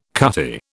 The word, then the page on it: cutty: British English